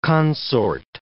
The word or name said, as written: consort